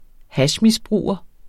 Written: hashmisbruger

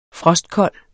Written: frostkold

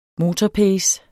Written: motorpace

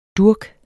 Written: durk

